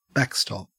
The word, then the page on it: backstop: Australian English